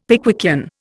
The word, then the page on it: Pickwickian: Indian